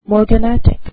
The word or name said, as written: morganatic